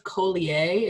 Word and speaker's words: Collier: I think his last name is pronounced Collier. It's Collier.